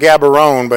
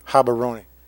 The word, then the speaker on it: Gaborone: I call it Gaborone, but it's Gaborone.